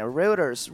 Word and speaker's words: Reuters: La empresa que la contactó se llama, eh, no sé ni pronunciarlo, Reuters, como Routers, pero con e, Reuters, Reuters.